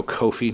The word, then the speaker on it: Coffey: Is Joe Coffey also Scottish? Or Joe Kofi, now that I know how to pronounce his name.